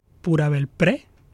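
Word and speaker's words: Pura Belpré: Pura Belpre.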